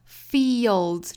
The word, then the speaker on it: fields: 我们再看后面两句， where the fields are green， fields 注意里头是一个紧音，然后加舌侧音舌侧音不能丢，那后面的这个 d z 拼在一起的辅音连缀读 z，所以是 fields 再跟后面做连读，有个小 schwa sound，所以呢应该读 z。